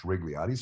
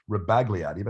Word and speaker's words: Rebagliati: Ross Rigliotti, I think his name is pronounced Rigliotti. Some people pronounce it Rebagliati, but I think it's Rigliotti Park. – It's a silent B.